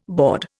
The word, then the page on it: baud: Indian